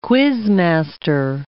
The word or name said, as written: quizmaster